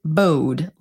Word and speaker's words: Bode: And I always pronounced it bode, like the word bode, like this doesn't bode well for us. But then when the series started, everyone was calling him Bode.